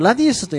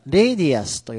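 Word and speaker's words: radius: これ、ラディウスと読む方いらっしゃいますが、まあ、一応正確には、レイディアスと読みますので、ボーダーレイディアスと読んでいただくのがいいんじゃないかなと私は思うんですが、ボーダーレイディアス、角丸ですね。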